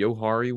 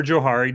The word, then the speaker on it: Johari: I'm going to probably mispronounce this word, but we have, is it the Johari window? – Yeah, that's good. Or Johari, depending on which way you want to say it.